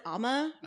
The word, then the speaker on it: Umma: It's spelled U-M-M-A. It's on Netflix, I think. They pronounce it Amma during the movie.